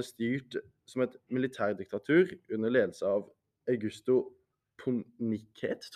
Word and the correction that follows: Pinochet: Det er, jeg tror du sier Pinochet. – Pinochet det, det, det, var det.